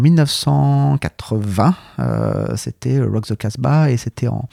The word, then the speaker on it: Casbah: Euh, alors c'est vous le prononcez avec en rajoutant un che à la fin. Peut-être que euh, dans d'autres régions du monde, ça se prononce comme ça. Non, c'est alors Rock the Casbah en fait, mais c'est la référence directe à un titre des Clash.